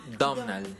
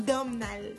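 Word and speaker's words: Domhnall: Se llama, no sé bien cómo se pronuncia porque es un nombre irlandés, pero se escribe Dom H. Nal. Seguramente es Domnal, ¿no? Domnal Gleason. – Pero es que esa MHN suena raro. – Seguramente se va a pronunciar de un modo completamente Ah, sí, bueno, si, se pronuncia Sarsha. – Ajá, pues eso va a ser Domnal.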